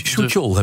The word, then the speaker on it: Suk-yeol: Soetjol heb ik laten vertellen. Soetjol, nou dankjewel.